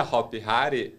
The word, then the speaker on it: Hopi Hari: Mas eu, acho muito, eu achei muito legal esse rebranding, e principalmente quando ela explicou, né, para falar justamente disso, que quando você pronuncia Hop Hari, é, é, muito claro pra gente a fonética do Hop, esse H, esse O.